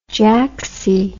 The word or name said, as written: jaxie